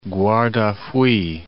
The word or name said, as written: Guardafui, Cape